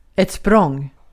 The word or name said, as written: språng